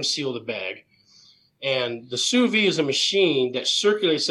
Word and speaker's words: sous vide: So it's called a sous vide. – Sous vide. So I kept saying it's a sous vide. – Yeah, it's spelled not like it sounds.